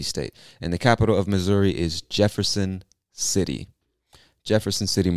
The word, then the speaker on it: Missouri: So I know if you're reading this, you see the two S's and you might think it's Missouri, but we pronounce it Missouri with like a Z sound.